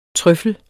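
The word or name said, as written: TRØFFEL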